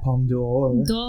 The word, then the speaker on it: Palme d'Or: Palme d'Or. – The Palme d'Or. – You gotta like... – D'Or. – You gotta almost say the apostrophe.